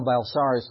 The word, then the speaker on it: Belisarius: So he sends his general, if I can say it right, Belisarius. Thank you. He sends his general Belisarius with about 5,000 troops.